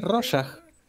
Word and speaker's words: Rorschach: Nadie puede pronunciar Rorschach. – es Rorschach.